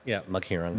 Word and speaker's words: Maghirang: I usually don't like spit it with like a thick accent, so just people. – It's just hard for me to not. – So people can just try to hit it by the syllables. – I tried to dull it down for Frankie and be like, just say like Maghirang. – Yeah, you give me the white version.